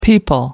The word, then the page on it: people: American English